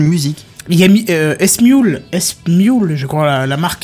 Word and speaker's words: Smule: Alors, ça se prononce euh, Smule, peut-être. Smule. En français, c'est Smule. S-M-U-L-E. – C'est comme Emule Ah ouais. Voilà, c'est ça, comme Emule, sauf qu'il y a un S elle passe de E, exact.